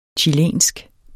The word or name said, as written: chilensk